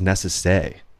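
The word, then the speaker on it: Nécessaire: You know, I didn't realize the name of Necessaire is Necessay. That's how you pronounce that.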